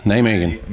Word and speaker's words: Nijmegen: Nijmegen.